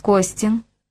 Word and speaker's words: Костин: «Алекс Костин», — сделал Игги ударение на последнем слоге.